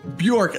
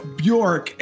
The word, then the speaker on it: Bjork: Bjork, Linda Bjork, and I meant to ask her that off air, but Linda Bjork, because I want to get it right.